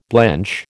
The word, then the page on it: blench: American English